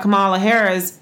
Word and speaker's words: Kamala Harris: I think the interesting thing about Kamala Harris is, am I saying her name right? – You are.